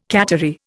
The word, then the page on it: cattery: Indian